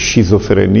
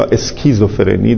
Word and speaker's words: اسکیزوفرنی: یک شیزوفرنی یا اسکیزوفرنی، در واقع حالا تلفظ های دو تا زبان متفاوته، زبان فرانسه و زبان انگلیسی،